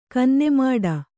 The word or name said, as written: ಕನ್ನೆಮಾಡ